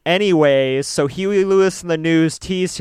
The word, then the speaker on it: Hughey: And on one occasion when his elder sister, Susan Huey Kemper. Not Huey, Dewey, and Louie. Like Huey, it's spelled H-U-G-H-E-Y.